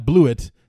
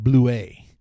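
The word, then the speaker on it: Blewitt: Not Bluet, the way it's spelled, but Bluet is how it's supposed to be. But now, apparently it's French or something like that.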